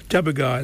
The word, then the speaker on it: Tjapukai: Oh, proud to be Aborigine, Jabbaga, all the way from North Queensland. I love that song, and some of their music, some of their didge playing is just amazing. Jabbaga, it's spelled TJAPUKAI, if you think of North Queensland.